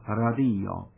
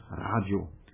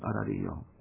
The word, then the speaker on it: radio: Im Esperanto die vorletzte, also Radio. Der Deutsche sagt Radio und wir sagen Radio.